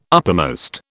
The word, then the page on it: uppermost: British English